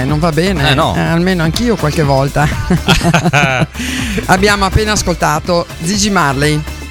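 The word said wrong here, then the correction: Ziggy: No, attenzione, no, Gigi. – Zigi.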